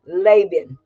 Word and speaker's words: Laban: Laban.